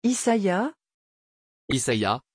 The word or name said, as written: isaïa